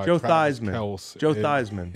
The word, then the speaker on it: Theismann: Theismann. – He changed his name to rhyme with the Heisman.